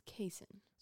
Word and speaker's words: casein: I'm pretty sure when I looked it up, it was casein. – Sounds like a Kansas, Missouri type of pronunciation. – Casein. – So we'll go with casein.